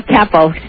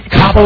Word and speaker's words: Capo: No, Capo. Capo like head of the mob.